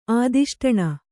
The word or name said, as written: ಆದಿಷ್ಟಣ